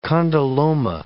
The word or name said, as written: condyloma